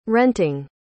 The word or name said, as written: renting